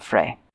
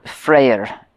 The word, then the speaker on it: Freyr: But first, we should talk about Frey. Or Freyr, as I guess the non-English, the non-lazy way to say it. Apparently it's sometimes spelt with an R at the end.